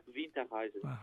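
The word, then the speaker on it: Winterreise: Es una palabra alemana, se pronuncia Winter Rise.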